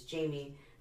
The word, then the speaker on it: Jamie: And so I was embarrassed about my name because my name is Jamie. I don't spell it Jaime. But in San Antonio, I got called Jaime Gonzalez my whole life.